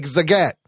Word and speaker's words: Zagat: He wrote, it's Maggett like Zagat.